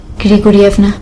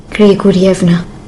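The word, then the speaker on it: گریگوریفنا (Григорьевна): آنا گریگوریفنا. – چقدر خوب تلفظ کردید، میشه یه بار دیگه بگین؟ بگین بگین. – گریگوریفنا. – بله خودشه.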